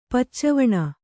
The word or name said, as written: ಪುಚ್ಚವಣೆ